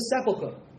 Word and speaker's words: sepulchre: We sometimes pronounce this word sepulchre. Sepulchre, no, huh? It's not sepulchre, but emphasis is on the first syllable.